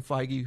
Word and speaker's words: Feige: I guess from what I was reading, falls somewhere between Kevin Feige, who is kind of the so that's how you say his name.